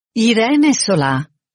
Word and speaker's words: Irene Solà: Irene Solà, I-R-E-N-E, S-O-L-A con accento.